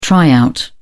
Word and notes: try out: British English

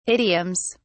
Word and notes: idioms: British English

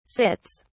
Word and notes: sits: British English

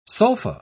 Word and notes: sulfa: British English

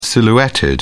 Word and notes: silhouetted: British English

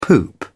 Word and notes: poop out: British English

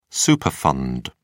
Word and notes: superfund: British English

